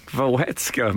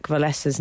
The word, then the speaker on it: Wałęsa: There is no K in Lech Wałęsa's name. – Oh, it's Lech... There's no... But don't they say Wałęsa? – Doing so, you have made his surname female. Please get your pronunciation right.